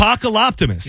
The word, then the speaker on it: Apocaloptimist: The AI Apocaloptimist. Apocaloptimist. – Oh, that's good. – Apocaloptimist. – Yeah, you just got it. – Like Hippopotamus, I think is how you pronounce it.